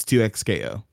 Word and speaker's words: 2XKO: That's how I know you didn't actually watch the trailer, because in the trailer they tell us exactly how to pronounce it. It's 2XKO.